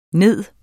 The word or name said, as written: ned